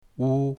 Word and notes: wu1: mandarin

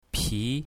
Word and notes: pi2: mandarin

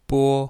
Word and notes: bo1: mandarin

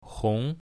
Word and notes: hong2: mandarin

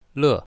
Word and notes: le4: mandarin

